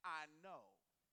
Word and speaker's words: aineo: The Greek word for praise here is spelled A-I-N-E-O. It's spelled A-I-N-E-O. When you pronounce it, it sounds like I know.